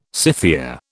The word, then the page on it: Scythia: British English